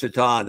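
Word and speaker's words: Šajtan: I tried to make a Miroslav Shaitan figure when I was at McFarland for years. Winger for the Buffalo Sabres. Pretty good player whose last name was spelled Satan. – Yeah. – Pronounced Shaitan.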